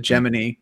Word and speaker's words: Gemini: they'll continue on with the Gemini program, or the Gemini, as they pronounced it in First Man, and then the Apollo program, and presumably Apollo Soyuz, space shuttles, space labs, stuff like that.